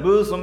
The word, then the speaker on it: Muslim: I went to one Muslim door, and the Muslim would be the correct pronunciation, but I went to the Muslim door, and a man offered me a drink of water.